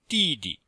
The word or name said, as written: di4di5